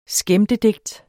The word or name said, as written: skæmtedigt